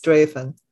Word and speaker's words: Strathaven: We went out to an Indian restaurant in Strathaven, spelled Strathaven, but it's pronounced Strathaven.